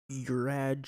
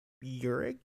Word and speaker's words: Juraj: Yeah, you pronounce his first name wrong. – What'd I say? – Slav? – No. It's, Jurij? His first name is J-U-R-A-J.